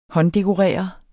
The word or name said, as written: hånddekorere